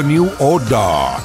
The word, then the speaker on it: New Order: que sí, que lo he dicho bien, ¿eh? que en Inglaterra siempre han dicho New Order.